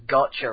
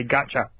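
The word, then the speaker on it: Gacha: Yes, that is the way that it is on the game that I'm playing, but the proper pronunciation, I think, or we tend to think, is a gotcha. So gotcha. This is a racing game.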